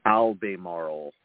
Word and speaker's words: Albemarle: One of the companies, Ali Marble, or hold on a second. I got to pronounce the name of it. Albemarle is the biggest lithium miner in the world.